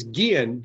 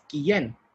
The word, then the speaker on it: Guillén: So, the interviews that I've seen with you, people pronounce your last name as Guillen, but there's an accent mark on the last syllable, so it's not Guillen. It's Guillen.